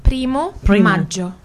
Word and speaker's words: Primo Maggio: Is it Promo Maggio Festival? – Primo Maggio.